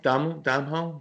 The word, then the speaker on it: Domhnall: Domhnall, did I say that right? – Sure.